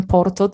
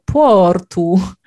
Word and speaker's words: Porto: Dlatego my tak naprawdę to nie jesteśmy, jak my mówimy o sobie, mieszkańcach Porto, to nie mówimy nawet, że jesteśmy Porto, tylko my jesteśmy tak naprawdę Portu,